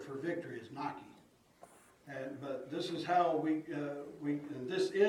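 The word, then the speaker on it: Nike (νίκη): It's a word called Nike. And it's spelled the exact same way that your shoes are spelled.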